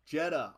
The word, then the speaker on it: Jeddah: Jeddah.